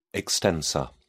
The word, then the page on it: extensor: British English